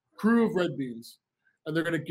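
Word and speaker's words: Krewe: right now go to the Google the Crew of Red Beans. Crew, spelled with a K, K-R-E-W-E, red beans.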